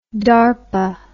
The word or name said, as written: DARPA